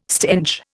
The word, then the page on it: stench: Indian